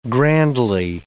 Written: grandly

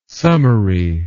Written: summery

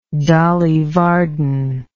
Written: Dolly Varden

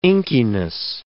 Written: inkiness

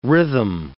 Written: rhythm

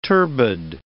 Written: turbid

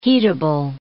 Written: heatable

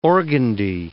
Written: organdie

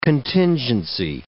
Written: contingency

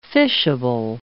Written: fishable

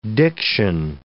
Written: diction

